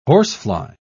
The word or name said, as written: horsefly